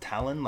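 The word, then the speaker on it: Talen: Talon? Like a... – Actually, it's cool. It sounds... Yeah, Talon, but it's T-A-L-E-N. Talon. Interesting. Not Talons, but Talon. Like Talon. I've never... – Well, he's got Talon.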